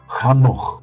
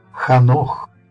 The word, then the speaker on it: Ханох: Правильно его имя на иврите будет звучать Ханох. И уверяю вас, мои дорогие, что вы никогда не пожалеете о том, что будете правильно произносить его достойное имя. Ханох.